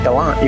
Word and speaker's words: Gallant: Am I saying that right? Gallant? – That's right, Gallant. You can just call me Gallant.